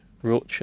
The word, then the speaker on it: Roach: Barry Roach, I know you were saying it like Roche, like Ferrero or something, but uh, the Irishman.